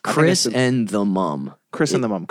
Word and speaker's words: chrysanthemum: It's Chris and the mum.